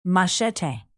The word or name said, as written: machete